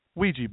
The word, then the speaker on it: Ouija: Ouija boards. It's spelled O-U-I-J-A, by the way. A lot of people are like, Ouija boards, I tried to Google that last night on the Googler, and I didn't find it. W-E-E-G-E-E.